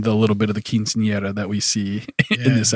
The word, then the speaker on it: quinceañera: Matt steps in, knocks on Carlota's door, and she is getting ready for a quinceañera. I probably said that incorrectly. – Yep, quinceañera.